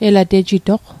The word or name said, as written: elatejitoq